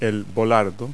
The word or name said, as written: bollard